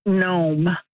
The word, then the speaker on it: gnome: O-M-E, mm-hmm. uncapitalized, and I'm wondering, is it gnome? – You have it correct. – With a silent G.